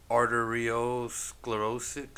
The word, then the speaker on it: arteriosclerotic: And the autopsy revealed that he had died from natural causes related to, I might be saying this wrong, but it's arteriosclerotic. – Yes. – Arteriosclerotic heart disease.